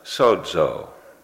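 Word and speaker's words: sozo (σῴζω): That's pronounced sozo.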